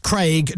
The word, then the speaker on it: Craig: It's Craig. It's pronounced Craig, not Craig.